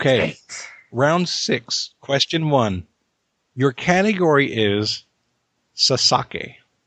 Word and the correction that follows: Sasuke: Your category is Sasuke.